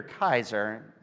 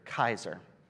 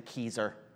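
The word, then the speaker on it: Keyser: I think it's Kaiser. It's K-E-Y-S-E-R. In German, you usually pronounce the second vowel as the loud one, but I'm not sure when it's a Y. But Kaiser is nicer than Kieser.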